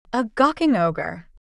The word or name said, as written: a gawking ogre